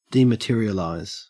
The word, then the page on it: dematerialize: Australian English